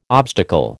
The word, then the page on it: obstacle: American English